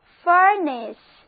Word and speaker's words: furnace: furnance，字母 A 读作 E，单词就读作 furnace，它含有 furn 这个词根，加上一个 ace 的名词后缀，就是火炉、熔炉。